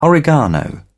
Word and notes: oregano: British English

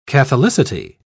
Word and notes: catholicity: British English

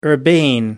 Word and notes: urbane: American English

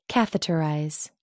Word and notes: catheterize: American English

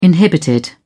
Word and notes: inhibited: British English

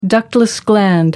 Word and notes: ductless gland: American English

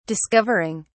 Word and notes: discovering: British English

